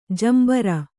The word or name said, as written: ಜಂಬೂರಿ